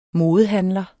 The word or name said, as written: modehandler